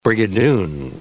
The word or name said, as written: Brigadoon